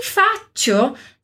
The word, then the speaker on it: faccio: Em ti faccio, também a gente tem uma consoante dupla aqui, uma doppia consonante, aqui no som do ti, ok?